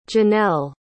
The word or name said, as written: Janell